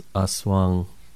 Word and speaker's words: aswang: Also, I think Aswang is pronounced Aswang.